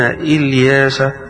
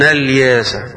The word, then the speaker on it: إلياس: فهنا يقال في القراءة اللي فيها قراءة الجمهور وإن إلياس لمن المرسلين، قراءة ابن عامر بخلفه وإن الياس، وإن الياس، فإذا بدأ بكلمة إلياس الجمهور يبدأون إلياس، وإذا بدأ ابن عامر قال الياس.